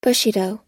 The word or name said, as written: bushido